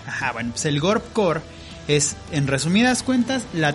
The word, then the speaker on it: Gorpcore: Eh, has interactuado más con este tipo de moda y bueno, el programa de hoy tiene que ver con el Gorp Core.